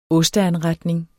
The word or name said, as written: osteanretning